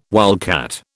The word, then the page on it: wildcat: British English